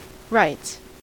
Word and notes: writes: American English

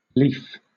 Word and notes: lief: British English

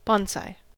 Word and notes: bonsai: American English

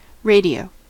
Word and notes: radio: American English

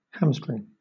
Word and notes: hamstring: British English

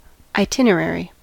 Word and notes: itinerary: American English

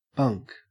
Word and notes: bunk: Australian English

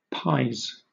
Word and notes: pies: British English

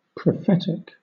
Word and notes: prophetic: British English

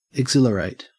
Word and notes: exhilarate: Australian English

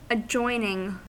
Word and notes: adjoining: American English

